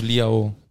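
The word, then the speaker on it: Leão: Liao. nehmen- Für mich ist Liao.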